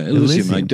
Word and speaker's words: Elysium: Elysium, yeah. Elysium. I pronounced it wrong. You know, the Greek paradise, pure land. – Elysium. Can you spell it? Because I think E-L-Y-S. Elysium. Elysium.